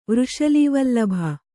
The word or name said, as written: ವೃಷಲೀವಲ್ಲಭ